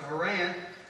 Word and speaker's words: Haran: Charan is just a New Testament rendition or pronouncement of Haran. Over there where it's spelled H-A-R-A-N.